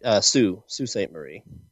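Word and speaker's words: Sault Sainte Marie: Salt Saint Marie is the Michigan Paracon you go to, right? – Right, Sioux Saint Marie. – Yeah. Oh, okay. They must have had it pronounced wrong, but it's the seventh annual MI Paracon. Yeah, it looks like Salt.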